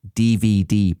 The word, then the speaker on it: DVD: So the main gag his character focused on was the word DVD player. So that is technically an initialism, meaning all three letters actually stand for something and we pronounce them individually. D, V, D.